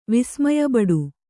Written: ವಿಸ್ಮಯಬಡು

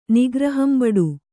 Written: ನಿಗ್ರಹಂಬಡು

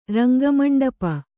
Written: ರಂಗಮಂಡಪ

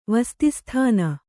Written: ವಸ್ತಿಸ್ಥಾನ

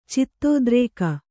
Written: ಚಿತ್ತೋದ್ರೇಕ

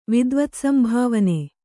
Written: ವಿದ್ವತ್ಸಂಭಾವನೆ

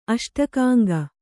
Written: ಅಷ್ಟಕಾಂಗ

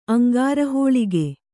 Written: ಅಂಗಾರಹೋಳಿಗೆ

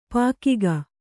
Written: ಪಕ್ಕಾಗು

